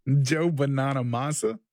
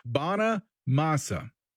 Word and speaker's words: Bonamassa: You find Joe Banana Manamasa? – It's Joe Banana Masa. Bana Masa. B-O-N-A-M-A-S-S-A.